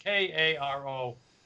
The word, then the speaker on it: Cairo: Well, they actually called it Cairo, but I have to say, they don't pronounce it that way. They call it Cairo. – Of course they do. – Maybe it was supposed to be Cairo, not Cairo. – Oh, it's spelled C-A-I-R-O. – Of course they call it. – It's pronounced K-A-R-O.